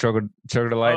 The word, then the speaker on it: Troglodyte: One of my favorite songs, probably, I don't know how to say it, Troglodyte or something. Yep. Troglodyte.